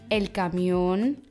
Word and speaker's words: camión: Erstens Aguda. Diese werden auf der letzten Silbe betont, zum Beispiel das Papier, el papel, der Lastwagen, el camión, Bethlehem, Belen. Die Wörter,